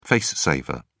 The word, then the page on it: face saver: British English